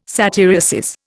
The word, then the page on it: satyriasis: Indian